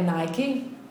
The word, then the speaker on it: Nike: L'esempio che fa è Nike, o Nike, Nike, ormai pronunciamo tutti Nike.